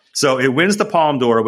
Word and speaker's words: Palme d'Or: How do you is it Palme d'Or? Is that how you say it, Jason? – The Palme d'Or.